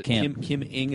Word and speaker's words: Ng: Is it Kim Ng? Is that how you pronounce it? – Yeah.